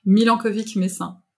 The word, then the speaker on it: Milenkovic-Messin: Déjà je ne sais pas comment prononcer ton nom, alors il faudrait peut-être que tu me dises avant que je fasse euh, avant que je l'écorche. – Milankovic Messin.